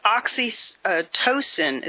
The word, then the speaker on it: oxytocin: Well, they're producing drugs, especially nasal sprays, with an estrogen-like hormone such as Zoxotocin. And critics have, you know, stated that, you know, such treatment makes people kind of apathetic towards nationalism. I'm sorry, it's oxytocin is how you pronounce that.